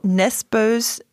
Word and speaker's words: Nesbø: Joe Nesbøs, wie spricht man das eigentlich aus? – Nesbø. – Also eigentlich ist es durchgestrichene O und Ö, oder?